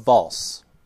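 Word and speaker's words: Walse: So if you recall, we were trying to get to the Water Crystal, which is in the town of Valse. At least I think it's pronounced false. It's W-A-L-S-E, and I am assuming a German pronunciation here.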